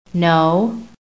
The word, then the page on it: NO: American English